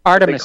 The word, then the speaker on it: Artemis: Hey, first of all, that Emeritus rocket, am I saying that correctly? – Artemis. – They call it the Emeritus. Artemis. I'm sorry. Yeah, I knew I had it wrong.